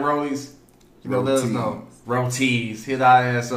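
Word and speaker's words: Roti: I heard of Roddy's, how do you pronounce that restaurant? Roddy's, R-O-T-I.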